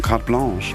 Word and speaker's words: Carte Blanche: Es wird Carte Blanche geschrieben und ich war so, wie spricht man das aus? Ja, es sind Franzosen. Carte Blanche.